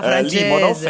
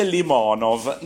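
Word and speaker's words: Limonov (Лимонов): perché lei il russo lo sa e quindi ho sicuramente sbagliato io dicendo Limonov e invece si dice Limonov.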